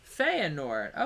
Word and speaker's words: Feyenoord: Feyenoord, the Dutch team. Did I not say that correctly? – Feyenoord. – Okay, well, I was close.